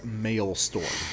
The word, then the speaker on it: Maelstrom: And I am not mispronouncing Maelstrom. It is Maelstrom.